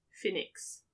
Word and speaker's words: Phoenix: But you, so speaking like, of the last name, how do you say that last name? – Phoenix. – Just or Phoenix. Or Phoenix? – Phoenix. – Okay. – Yeah, there we go, you got it. – Phoenix. – Yeah, so it was like P-H, accent aigu, N-I-X.